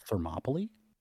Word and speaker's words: Thermopylae: 300 of Thermopylae, like same kind of thing, this like last stand sort of great, you know, overcoming. – Did you say Thermopylae?